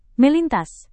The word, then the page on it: melintas: Indonesian